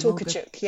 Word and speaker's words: Tokarczuk: Tokarczuk, Olga.